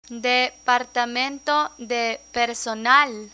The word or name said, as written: departamento de personal